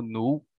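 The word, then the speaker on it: Knull: Kinu, né? Uh, em inglês eles eles comentam, eles uh, não pronunciam o K, se eu me lembro bem, então é tipo nu, mas eu vou pronunciar o K, porque.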